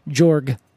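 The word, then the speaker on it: Georg: Without the E. – Oh, yeah, I believe it's pronounced Jorg.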